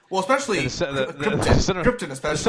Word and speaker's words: Krypton: For me, you really can't beat the first half hour of Superman with the origin and Krypton, him getting to Earth. – Please pronounce it correctly. Pronounce it like Marlon Brando. Krypton.